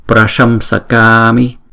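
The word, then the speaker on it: praśaṃsakāmi: Chữ s này là chữ s bình thường như là màu xanh đó, prasham sakami, prasham sakami.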